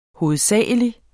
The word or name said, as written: hovedsagelig